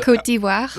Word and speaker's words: Côte d'Ivoire: Côte d'Ivoire.